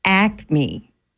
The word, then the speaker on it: Acme: It is Acme. – Can you spell it? – A-C-M-E.